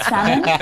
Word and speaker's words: salmon: Words like salmon, not salmon.